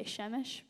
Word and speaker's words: Šemiš: I know that doesn't look like it would say Šemiš, but that's how you, that's how you say it.